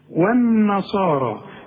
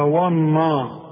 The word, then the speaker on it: والنصارى: خلاص، والذين هادوا خدتها أنت دلوقتي، هادوا كلها مرققة، والنصارى يا ريتك تنطقها زي ما أنا نطقتها، انما انت هتقول لي والنصارى والنا، شوف وا والنا غلط، ده انت لسه قايل دلوقتي والذين، الواو دائما مرققه، هنا ليه فخمتها لي قلت لي وا والنصارى؟